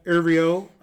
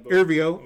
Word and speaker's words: Erivo: And, you know, this has Ariana Grande as Glinda and Cynthia Erivo. – You butchered that name horribly. – Cynthia Erivo as Elphaba.